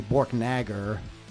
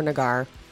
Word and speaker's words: Borknagar: The A would be long because there's only one. – Yeah, that's why. – Thing at one consonant after it like that, so it wouldn't be Borknagar. Borknagar.